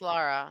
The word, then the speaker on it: Lara: So like I think your writer Lara, did I just say her name wrong? Oh my gosh, don't kill me. No, it's Lara.